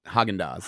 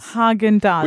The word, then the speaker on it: Häagen-Dazs: Häagen-Dazs. That's it. – See, then now you know how to pronounce it. Häagen-Dazs. – Häagen-Dazs.